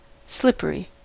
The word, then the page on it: slippery: American English